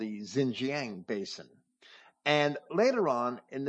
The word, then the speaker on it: Xinjiang: This Xinjiang, as Comparet pronounces it, or Xinjiang, Xinjiang, is how it's spelled in modern dictionaries and encyclopedias.